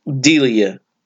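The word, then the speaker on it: D'Elia: It is starring Johnny Weston as David, Sophia Black Diella as Jesse. – Delia. – Yes, there's an apostrophe type thing, so I didn't know how to say it quite right.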